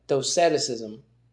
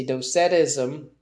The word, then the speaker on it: Docetism: I don't know if that's how you pronounce it. It's D-O-C-E-T-I-S-M. D-O-C-E-T. ISM, Doceticism, Docetism, I was adding an extra syllable there, Docetism, which denies Christ's true humanity, So I said, well, I said, what is that?